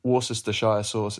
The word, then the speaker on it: Worcestershire: I can confirm that the pronunciation of Worcestershire sauce is, in fact, Worcestershire sauce. Worcestershire sauce.